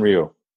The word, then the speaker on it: Ryu: Look, Kershaw pitched a gem the other day, and I can't pronounce the first guy's name. Ryo? – Yeah, Hyunjin Ryu.